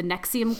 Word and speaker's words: NXIVM: So I did the Nexium cult, or it's spelled N-X-I-V-M, like Roman numeral style.